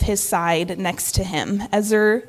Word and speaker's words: ezer: But the word helper in Hebrew is actually the word ezer. And did I say that right? – You did.